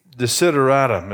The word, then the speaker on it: desideratum: Think of the word consider. The word is sideri is the root. Desideratum is the word.